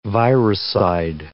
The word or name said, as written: viricide